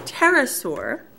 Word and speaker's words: pterosaur: The P is silent. It's pterosaur. – Then why have it if it's silent? Why not just start T?